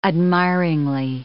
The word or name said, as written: admiringly